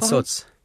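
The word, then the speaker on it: Calçots: Aber zu Kalkotz. Die ich jetzt aber nicht richtig ausspreche, oder? Wie werden die ausgesprochen? – Calzots.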